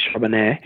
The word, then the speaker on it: Charbonnet: He is, and good pronunciation, by the way, is Charbonnet.